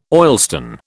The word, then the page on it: oilstone: British English